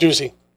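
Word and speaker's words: Doocy: Is that Ducey? – Yep, Ducey. – Ducey. I had to look it up. Did you know the definition? – Of Ducey? – Yeah. – Yeah, Not Ducey, because I know Ducey.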